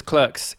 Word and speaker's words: Clerks: We're going to struggle because Clerks is pronounced Clerks because it's an American film. – Mm-hmm. – And it's not going to be pronounced the correct British English way of Clerks, which Ben also doesn't understand.